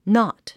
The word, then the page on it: knot: nάt; ナット